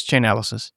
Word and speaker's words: Chainalysis: Am I pronouncing it? – There are different people who pronounce it in different ways for different reasons, but it's Chainalysis.